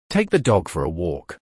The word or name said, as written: Take the dog for a walk